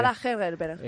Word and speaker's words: Ada Hegerberg: Curiosidad, la futbolista que ha metido los 3 goles, que se pronuncia ¿cómo exactamente? – Ada Hegerberg.